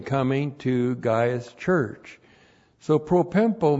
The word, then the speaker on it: propempo: And then we have the verb propempo. Propempo. P-R-O-P-E-M-P, long O. Propempo.